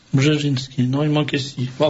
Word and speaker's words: Brzezinski: C'est le bouquin de Zbigniew Brzezinski. Ah oui, ça s'écrit comme ça, ne se prononce pas. Brzezinski, c'est B-R-Z-E. – Il y a toujours des S qui... Alors, B-R-Z-E...